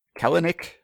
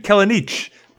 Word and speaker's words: Kelenic: I've decided that I think there's like three distinct pronunciations of Kelenic, so I'm going to provide all of them. Yeah, so okay. – It could be like Eastern European Kelenich, but it's probably not.